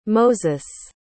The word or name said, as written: moses